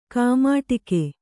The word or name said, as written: ಕೈಮಡಗು